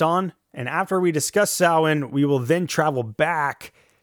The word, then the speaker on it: Samhain: And if you don't know what Samhain is, it's the Celtic Irish festival spelled out as S-A-M-H-A-I-N, but pronounced Samhain. Phonetically, there's a couple other ways that it's been spelled out, such as S-O-W, as in cow, and then when, W-E-N. But I think of it as S-A-U-W-E-N, Samhain.